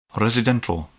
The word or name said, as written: residental